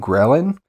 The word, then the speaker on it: ghrelin: One of the other things that sleep deprivation does is that your body generates higher levels of the hunger hormone ghrelin, which results in a, I don't know if I'm saying that right. It's G-H-R-E-L-I-N.